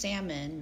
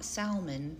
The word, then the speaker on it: salmon: And then we look at 4 ounces of grilled sockeye salmon, which is pronounced salmon, not salmon, people.